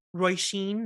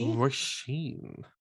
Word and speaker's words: Roisin: Roisin Murphy. – Roisin. – Mm-hmm. – Oh, that's lovely. – And I only know that because I watched an interview with her and I heard her say her name and I was like, clocked, bet, now I know.